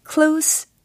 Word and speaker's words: close: 이처럼 뭐뭐에 가까운이라는 형용사로 표현을 할 때는 close 이렇게 마무리하셔야 되는 발음이고 어, 닫다, 뭐 문을 닫을 때, 창문을 닫을 때의 동사는 close 이렇게 z로 끝난다라는 거 참고로 알려드릴게요.